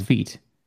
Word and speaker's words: Vite: I thought it was Vite. It was the French word for fast. And so I think it's, no, I thought it was Vite.